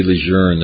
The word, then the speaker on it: Lejeune: Why do you say Lejeune? There's no R in it. It's the way the family pronounced it, right?